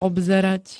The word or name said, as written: obzerať